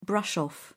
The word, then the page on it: brush off: British English